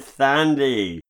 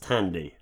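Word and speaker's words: Thandie: You said Thandy. As far as I know, it's pronounced Tandy.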